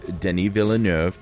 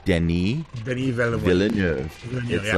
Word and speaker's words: Denis Villeneuve: That's how you pronounce his name. – Yes, exactly. – It looks like Denis Villeneuve. – Denis Villeneuve. – Like Villa and Nerve, but you drop the R.